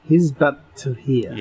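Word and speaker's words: Hizb ut-Tahrir: went to report on the radical Islamic group Hizbut Tahrir. Is that how you pronounce it? – Hizbut Tahrir. Yeah.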